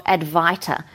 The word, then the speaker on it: Advaita: And then also you can look up the ancient Eastern philosophy of Advaita, and it's spelled A-D-V-A-I-T-A.